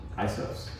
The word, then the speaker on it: isos: The I-S-O-S. Let's take a step. – Isos. – Pretty close. Isos, yeah. Isos, Isos.